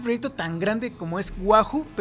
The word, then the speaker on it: Wahu: Wahoo, que se escribe W-A-H-U, viene de We Are Humans en inglés, que es Todos Somos Humanos.